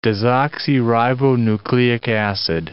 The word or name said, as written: desoxyribonucleic acid